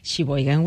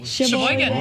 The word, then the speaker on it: Sheboygan: Ella es originaria de Sheboygan, Wisconsin. ¿Cómo? How do you say? – Sheboygan. That was correct. Sheboygan.